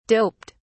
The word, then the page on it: doped: British English